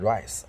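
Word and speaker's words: R1SE: 周震南啊，就是某偶像团体组合 Rise 啊，我经常说叫 R E S E 嘛，因为那中间不一直是 E 嘛，对啊，而不是 I 嘛，对我也不知道到底怎么念啊，说是要念 Rise 啊，呃，队长。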